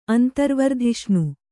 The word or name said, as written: ಅಂತರ್ವರ್ಧಿಷ್ಣು